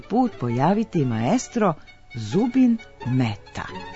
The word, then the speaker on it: Mehta: Na hindi jeziku, koji je jedan od službenih u Indiji, odakle je Mehta rodom, njegovo ime zaista se izgovara Mehta, a pisani oblik preuzeli smo iz engleskog.